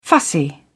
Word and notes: fussy: British English

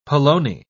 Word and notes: polony: British English

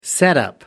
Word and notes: setup: British English

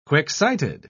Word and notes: quick-sighted: British English

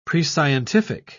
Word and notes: prescientific: British English

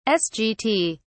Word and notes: sgt: British English

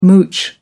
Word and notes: mooch: American English

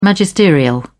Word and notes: magisterial: British English

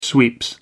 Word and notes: sweeps: American English

